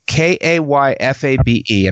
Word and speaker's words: kayfabe: And the original carnival term is K A Y F A B E. And I was going to pronounce that kayfabe. – Yes, you are right.